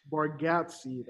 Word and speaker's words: Bargatze: Bargatze, that's how you pronounce it.